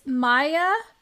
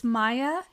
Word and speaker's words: Maja: It's Maya, M-A-J-A is how it's spelled, but it's Maya Radinovic.